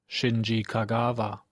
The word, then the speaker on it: Shinji Kagawa: Aber auch da hat die ARD-Aussprachedatenbank eine ganz klare Vorgabe, wie der heißt. – Shinji Kagawa.